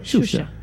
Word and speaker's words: Xuxa: No, pues la X en portugués se pronuncia como una CH. ¿No, claro, cierto? Y por eso se lee... – Claro. Xuxa.